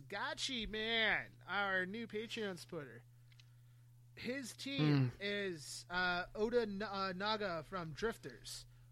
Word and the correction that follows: Oda Nobunaga: Oda Nobunaga. Did you just pronounce Oda Nobunaga wrong? – I think I did.